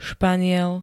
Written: Španiel

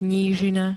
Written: nížina

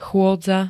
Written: chôdza

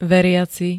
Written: veriaci

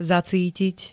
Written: zacítiť

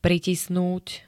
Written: pritisnúť

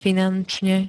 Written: finančne

